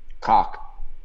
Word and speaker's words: Koch: And, of course, Mark Cook, which we like to say Mark Cock, but, hey, how would you pronounce K-O-C-H, Cook or Cock, whatever? That's a good question. How would you pronounce that, K-O-C-H? – Cock. – See? Cock. See? Yeah, I was like, is it Cook? Is it Cock?